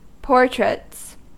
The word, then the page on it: portraits: American English